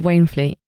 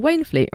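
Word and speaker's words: Waynflete: Would you like to know the worst bit, which is that if you are an old Wainfleet, which is spelt Wainflet, just so we're clear, if you are an old Waynefleet, you might think that means that you went to a school called Waynefleet, right?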